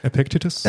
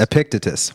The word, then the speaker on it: Epictetus: Epictetus. – Epictetus.